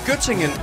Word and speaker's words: Göttingen: Var det ved Gødringen? – Nej, Göttingen. – Göttingen, ja, men det Göttingen ost. – Jamen, det er bare mig, der ikke kan udtale det korrekt.